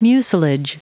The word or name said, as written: mucilage